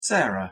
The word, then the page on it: Sarah: English